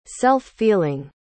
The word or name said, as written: self-feeling